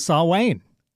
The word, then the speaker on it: Samhain: Because of Samhain. – Yeah. – Which is spelled Samhain, I think. – Yep. – Yeah. – And that's exactly what it is. So you're saying it right.